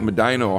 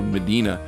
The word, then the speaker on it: Medina: kind of a somewhat cynical approach, but yeah, we do have a tendency here in Ohio to change the pronunciation, just like Medina, Ohio. Anywhere else would be called Medina, but.